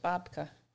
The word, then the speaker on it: babka: My apologies. I mispronounced it. It's babka.